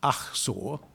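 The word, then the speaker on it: Achsah: So, for better or for worse, I'm going to suggest tonight that it should be pronounced Achsor, which is probably closer to the Hebrew, but I'm not going to hold you responsible if you don't.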